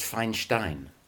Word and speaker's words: Feinstein: What it actually is Feinstein. – Right. Okay. – But everybody pronounces it differently, so I go by whatever I'm called. the way my parents always pronounced it was Feinstein. I have no idea why.